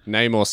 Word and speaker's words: Namor: Namor sounds more correct to me.